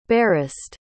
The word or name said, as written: barest